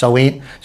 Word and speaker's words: Samhain: Halloween is named after Samhain, originally pronounced Soween.